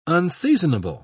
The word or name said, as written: unseasonable